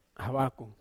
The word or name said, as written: hawakou